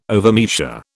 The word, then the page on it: overmeasure: British English